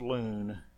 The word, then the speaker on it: luwn: The Old Testament word that best comprises the meaning of complain is Hebrew word L-U-W-N, in English pronounced loon.